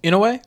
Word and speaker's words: Ino: How you say her name? – Inoue? No, Ino.